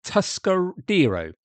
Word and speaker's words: Tuscadero: She was Leva Tuscarido, or Tuscadero. There we go. Get it pronounced correctly.